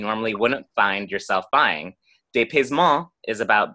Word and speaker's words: dépaysement: So this first word I can pronounce, and it's pronounced dépaysement, which is the French word, which is closely related to the word disorientation.